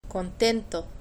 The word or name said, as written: contento